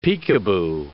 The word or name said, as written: peekaboo